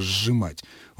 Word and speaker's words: сжимать: Когда мы произносим слово «сжимать», у нас звучит вначале не «с», «ж», а «ж», долгий «ж», «сжимать». А на письме это обозначается приставкой «с» и первой буквой корня «ж». Отсюда, естественно, стремление разделить их.